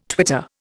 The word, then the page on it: twitter: Indian